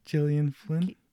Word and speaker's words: Gillian: So the screenplay is by Gillian Flynn. Do you know how to pronounce her name? – I've heard Gillian. – Yeah, it's with a G. – Yeah, like GIF. It's a GIF. – Gillian Flynn.